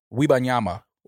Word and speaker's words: Wembanyama: That was an accurate Victor Wembanyama. Wembanyama. – No, you said it right every single time, by the way.